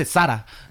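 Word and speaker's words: Zara: Por ejemplo, cuando dice Sara y la banda le está diciendo, Sara es una marca española, pendeja. Se dice Sara.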